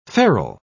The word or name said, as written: ferrule